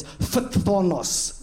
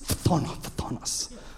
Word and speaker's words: phthonos: I like this one because I tried to pronounce it for ages. It's phthonos. Phthonos.